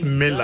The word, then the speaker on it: Milla: His surname is spelled M-I-L-L-A. – Yeah, but pronounced Miller, as in Roger Miller.